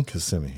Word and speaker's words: Kissimmee: Is it Kissimmee? – Kissimmee, yep. – Yes, I said it right.